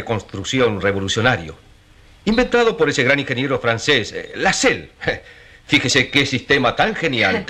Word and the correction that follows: Lasselle: Se escribe Lasselle, se pronuncia Lasselle. Héctor, tú que estudias francés, ¿verdad que es Lasselle? – Bueno, sí. Es como dice mamá.